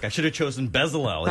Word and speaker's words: Bezalel: Bezalel.